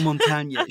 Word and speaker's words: Montagne: So they were Montang Sport, like I'm not even going to try and pronounce it in French, but you know, like mountain in French. – Oh, Montagne. Sure, of course, Montagne.